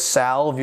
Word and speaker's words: salve: You're watching and you say, excuse me, this clearly says salve. You've pronounced it wrong.